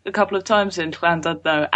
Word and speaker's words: Llandudno: Llandudno, I would have said. – Llandudno, no. Llandudno. – Llandudno. – Close.